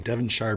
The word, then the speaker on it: Devonshire: Probably pronouncing it wrong. Devonshire, Bermuda. – That sounds right.